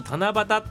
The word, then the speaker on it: 七夕: はいまず七夕っていうね漢字でございますね七夕ね、 7 の U って書いて七夕って読むん